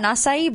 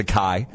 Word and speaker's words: acai: Yeah, I thought it was a kai. – No, acai, but I had to do the little,